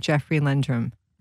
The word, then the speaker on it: Jeffery Lendrum: Josh, this book focuses on a man named Jeffery Lendrum.